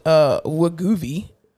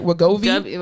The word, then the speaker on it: Wegovy: But yeah, so the medicine is actually called Wegovy. It's spelled Wegovy. Wegovy. – Wait, one more time. Give it to me. – Wegovy. Wegovy.